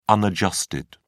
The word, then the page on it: unadjusted: British English